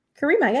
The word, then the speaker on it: Caryma: Caryma, yeah, you got it.